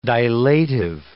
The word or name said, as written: dilative